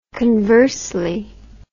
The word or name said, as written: conversely